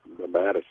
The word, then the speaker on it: mammatus: And I may mispronounce this, but I believe they're called Mammatus clouds. – Mammatus, yes.